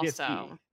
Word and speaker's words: GIF: GIF-y. Obama took a side in 2014 announcing his official position is that it's pronounced GIF like a hard G, like a grape.